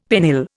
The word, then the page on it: pennill: Indian